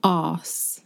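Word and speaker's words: arse: Yeah, so it's A-R-S-E, and we don't pronounce the R, so you don't really hear the R. It's non-rhotic, so it's ass. Mm-hmm. We just elongate that first sound.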